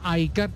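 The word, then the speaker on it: iCAR: Aycar, ahora se escribe Aycar, se pronuncia Aycar,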